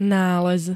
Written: nález

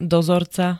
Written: dozorca

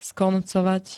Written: skoncovať

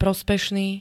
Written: prospešný

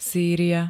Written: Sýria

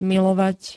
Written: milovať

